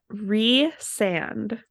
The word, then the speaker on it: Rhysand: How does the ACOTAR wiki say it is the correct way to pronounce his name? – Rhysand. – Correct. Ding. – Yay. – R-E-E-Sand.